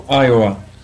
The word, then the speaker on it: Iowa: É Iowa ou Iowa? Como que é a pronúncia certa, hein? – No Iowa. – Iowa, em Iowa. E teve...